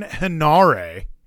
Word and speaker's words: Henare: United Empire, Aaron Henare. Is that how you pronounce that? – Yes, I believe so.